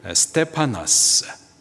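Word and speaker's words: 스테파나스 (στέφανος): 면류관, 헬라식 발음으로 스테파나스, 승리의 관입니다.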